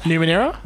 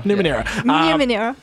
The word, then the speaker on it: Numenera: We've got Torment, Tides of Numenera. Am I saying it right? – That's close enough. – Numenera. – Yeah. Numenera.